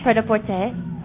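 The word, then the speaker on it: prêt-à-porter: Fred a Porter. – Fred a Porter. – That's what it is. I knew I was missing a syllable there somewhere.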